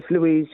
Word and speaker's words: Louise: Is it Louise? Louis? – Yes, Louise, yeah.